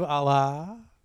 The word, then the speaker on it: Allah: The Sword of Allah.